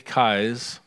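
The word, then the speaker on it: Keyes: There's a quote, and I think Dan has it ready on a slide, from Chameleon Christianity by Dick Kies. That's how you pronounce his name.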